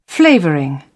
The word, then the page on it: flavouring: British English